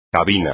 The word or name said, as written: cabina